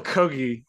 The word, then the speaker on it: Kogi: Like, one of the most striking moments for me, Michael Kogi, and it is indeed pronounced Kogi. Let's confirm that again. – That's Kogi. It is pronounced Kogi.